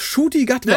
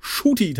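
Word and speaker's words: Ncuti Gatwa: Und das ist, und ich habe tatsächlich geübt, es ist Shooty Gatwa. Hey. Ja, ich dachte erst, er ist ein Kuti. Ja, also so wie hab man, ich auch ausgesprochen. – Aber nein, es ist äh, Shooty tatsächlich.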